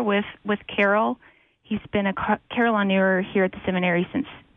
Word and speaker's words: carillonneur: It's pronounced carillonneur, right? – That's correct.